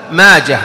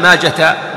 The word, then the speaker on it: ماجه: هذه الثلاثة كلها بالهاء، ماجه ومندة وسيدة، ما يقال ماجة مندة، لا خطأ، لأنها أسماء فارسية تنطق بالهاء وقفا ووصلا.